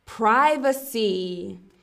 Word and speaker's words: privacy: Our next word is privacy. So we have three syllables. Privacy. Or some people will say privacy.